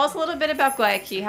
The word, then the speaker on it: Guayaquil: It's like Why a key if you're at a door, but yeah.